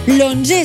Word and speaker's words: onzè: L'onzè s'apostrofa perquè encara que estigui escrit en números, es pronuncia amb una O, l'onzè, i per tant s'apostrofa.